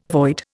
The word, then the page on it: void: Indian